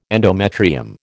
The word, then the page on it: endometrium: American English